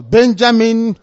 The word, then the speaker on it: Benjamin: The prime minister of Israel, Benjamin, that's the correct pronunciation, Netanyahu, is seeing the son off into the military.